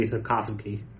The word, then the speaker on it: Sapkowski: I mean, come on, it's Andrzej Sapkowski, I'm assuming? – That's definitely a V sound. It's got to be Sapkowski.